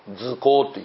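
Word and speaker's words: 塗香: それでそのぬる香と書いて図香というふうにこの言うわけなんですね。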